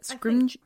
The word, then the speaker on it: Scrimgeour: And so, you know, Harry insists to Scrimgeour, is this how we're pronouncing it? – Scrimgeour.